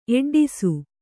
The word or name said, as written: ಎಡ್ಡಿಸು